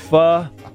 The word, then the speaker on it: pho: The Vietnamese soup, P-H-O, is pronounced pho.